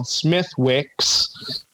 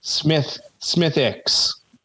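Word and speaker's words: Smithwick's: I prepared for this call today, but I actually learned today it was not called Smithwicks. It's pronounced Smithicks. The W is apparently silent.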